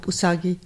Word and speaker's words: Usagi: Usagi oder Usagi? – Ja, Usagi.